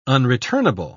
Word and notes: unreturnable: British English

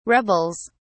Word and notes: rebels: British English

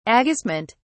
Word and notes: agistment: British English

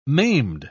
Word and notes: maimed: British English

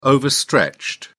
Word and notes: overstretched: British English

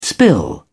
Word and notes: spill over: British English

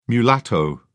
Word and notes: mulatto: British English

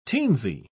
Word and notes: teensy: British English

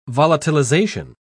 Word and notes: volatilization: British English

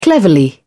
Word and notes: cleverly: British English

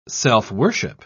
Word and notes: self-worship: British English

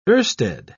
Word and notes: oersted: British English

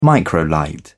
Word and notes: microlight: British English

